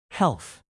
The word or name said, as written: health